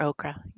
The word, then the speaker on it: ochre: Some people say ochre or okra, yeah.